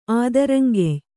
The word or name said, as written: ಆದರಂಗೆಯ್